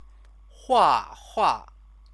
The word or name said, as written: huahua